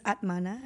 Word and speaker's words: Atmana: And you're known as Atmana.